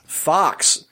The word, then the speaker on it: Fuchs: Fox, Fox, that's how you pronounce that name. – Yes, F U C H S.